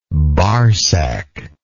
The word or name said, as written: barsac